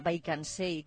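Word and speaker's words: Wijk aan Zee: Bueno, estás en ese pueblo holandés cuyo nombre se pronuncia Baikansee,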